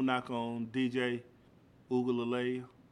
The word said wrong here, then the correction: Uiagalelei: Uiagalelei. I think I said it wrong the first time. I got it right.